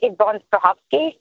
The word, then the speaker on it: Yvonne Strahovski: I think Yvonne Strahovski from Handmaid's Tale, Dexter.